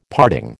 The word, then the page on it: parting: American English